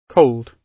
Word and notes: cold: British English

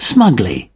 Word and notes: smugly: British English

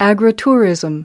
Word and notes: agritourism: American English

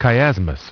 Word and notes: chiasmus: British English

